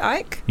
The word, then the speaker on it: Ike: Is that, have I pronounced that right? – Yeah. – Okay. – Ike. Good. Ike.